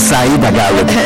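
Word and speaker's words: Siedah Garrett: Así es, y bueno, ya extrañaba la pronunciación perfecta que tienes, porque así es Zaira Garrett, tienes toda la razón.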